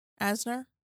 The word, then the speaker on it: Asner: So, first one, Ed Asner. Asner? – Uh-huh. – I don't want to say it wrong. – Yeah, that's right.